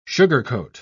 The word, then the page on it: sugarcoat: British English